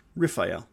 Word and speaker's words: Raphael: Raphael, pronounced Raphael. Um, with these pronunciations, I'm going to present uh, pronunciations that are less um, less Latin English type pronunciations, and I'm going to stick more with the Hebrew pronunciations uh, which are a little older.